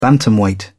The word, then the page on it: bantamweight: British English